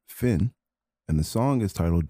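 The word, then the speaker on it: Fionn: The band's name is pronounced Finn, but because it is spelt with F-I-O-N-N, I had the hardest time trying to figure out how to spell their name.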